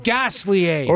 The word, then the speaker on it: Gosselaar: Mark Paul Gosselaar. Actually, it was Mark Paul Gosselaar's father who introduced me to Harry Hart. – Pronounced Gosselier.